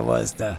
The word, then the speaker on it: Worcester: You say it like an out-of-towner. Absolutely. Like Worcester, Mass. Is where Worcester.